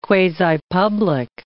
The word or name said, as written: quasi-public